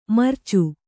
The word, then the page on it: ಮುರಿಚು: kannada